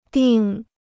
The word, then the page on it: 订: dìng